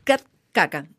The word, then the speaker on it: kladdkaka: Lo hemos pronunciado bien, quiero decirte. – ¿Ah, sí? – Kladdkaka. – ¿Qué tal? – Con dos acentos, la primera y la...